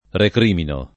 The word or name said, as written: recriminare